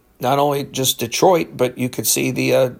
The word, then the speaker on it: Detroit: So Detroit, I know some of us say Detroit, but I've learned that it's Detroit.